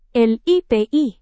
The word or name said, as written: lipi